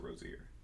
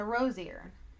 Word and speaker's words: Rozier: There's an NBA player that has it spelled the same way. He goes, and he says it Rozier. – Rozier? – Yeah. – All right, so Rozier.